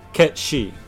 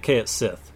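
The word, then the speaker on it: Cait Sith: I say Ketshi. And the reason I say it that way is because the Irish cat fairies... the word she is fairy in that sort of Irish Celtic lore. And so it's Ketshi. But... For a long time, I said it Kaet Sith. – Because that's how it's spelled.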